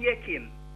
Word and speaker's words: Yekim: Yes, I am Yekim. – How do you spell that? – Y-E-K-I-M.